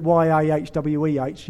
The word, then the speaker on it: Yahweh: We put vowels in it to make it possible for our English tongues to get around it. We spell it YAHWEH, Yahweh.